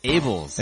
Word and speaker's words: Abels: Abels.